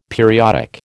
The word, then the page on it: periodic: American English